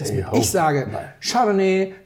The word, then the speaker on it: Chardonnay: Keiner sagt Chardonnay. Außer du möchtest besonders kosmopolitisch klingen. Und das ist eine der Belehrungen, die mir leider relativ oft begegnet. Also eine leichte Betonung auf der 3. Silbe, alles kein Thema.